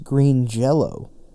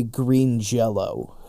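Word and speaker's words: Green Jellÿ: But well, actually, that's pronounced Green Jello. Just so you know, they originally called Green Jello, but then Jello made them change their name, and although it is spelt Green Jelly, the little accent or whatever it is, the two little dots over the Y, I believe, makes it say Green Jello.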